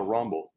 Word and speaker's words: Rumble: Se pronuncia Rumble.